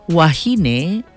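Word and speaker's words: wāhine: Wahine is singular for a woman. Wahine, with the kahako or the makron over the A, pronounced wahine, is plural for women.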